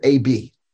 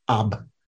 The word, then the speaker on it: ab: So we said that the short A sounds like ah in the English word alike, and the word I gave you to practice was the word AB, ab, ab. That's how you pronounce it, ab.